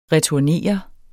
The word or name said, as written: returnere